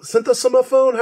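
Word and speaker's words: Sinthasomphone: Synthosomophone? How do you say that? – Synthosomophone.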